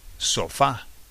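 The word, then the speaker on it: sofá: And it's called sofa. Sofa. So nice and straightforward. It's virtually the same word, isn't it? The emphasis is on the end of the word, on the a, sofa.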